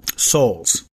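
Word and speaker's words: Soules: Souls.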